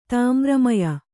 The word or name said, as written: ತಾಮ್ರಮಯ